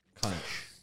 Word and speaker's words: conch: Some pronounce it conch. Some call it conch. But it's spelled C-O-N-C-H.